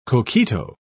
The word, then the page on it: coquito: British English